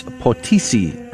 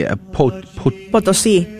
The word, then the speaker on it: Potosí: Otherwise known as Conchita, she was born on the 8th of December, 1862, in San Luis Potosí. How do you say that? – Potosí. – Potosí. I knew that it was said better than that.